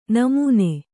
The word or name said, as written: ನಮನ